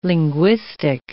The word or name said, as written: linguistic